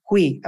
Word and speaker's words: Hui: Hui, if I say it right, it's like we, it's H-U-I-H-U-I. She said, Hui, Hui.